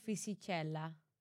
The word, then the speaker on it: Fisichella: I am going to pronounce this so wrong. Giancarlo Fisichella. – So yes, can pronounce that a lot better than I can.